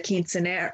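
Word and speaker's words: quinceañera: And, you know, I think that the celebrations like the quinceanera, how do you say quinceanero? – Quinceanera. Quinceanera. Yes.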